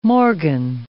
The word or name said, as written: morgan